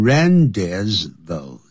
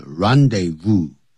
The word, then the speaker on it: rendezvous: 这个时候不能念成 rendezvous 那不是因为不是英文是 rendezvous